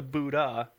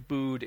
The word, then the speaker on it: Buddh: Remember, we've had this debate just a little bit, and I expressed to you my Indian friend's contention that it should be pronounced the Buddha International Circuit, not the Bood International Circuit. And basically what he's saying is the name is Buddh. That's how you spell Buddha. It doesn't spell anything else.